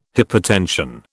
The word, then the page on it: hypotension: British English